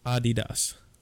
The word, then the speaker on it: Adidas: You know how it's actually pronounced? – Is it like some stupid, like Adidas? – Almost. It's legitimately pronounced Adidas. – Adidas? – Adidas. – Get out of here. – Yeah. Well, it's German, obviously.